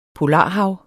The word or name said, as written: polarhav